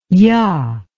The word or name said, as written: yah